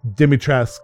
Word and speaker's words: Dimitrescu: Sorry, Dimitrescu, because the U is not pronounced.